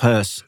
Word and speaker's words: Höss: So Christian Friedel plays, correct my pronunciation, Rudolf Huss. – Well, it's Rudolf Hess, yes, because it's an O with an umlaut.